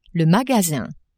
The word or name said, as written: magasin